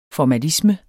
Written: formalisme

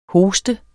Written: hoste

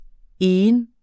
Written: egen

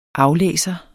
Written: aflæser

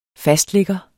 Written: fastligger

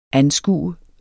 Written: anskue

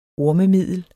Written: ormemiddel

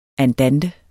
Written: andante

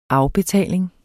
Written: afbetaling

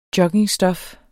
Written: JOGGINGSTOF

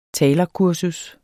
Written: talerkursus

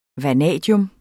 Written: vanadium

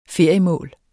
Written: feriemål

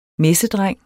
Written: MESSEDRENG

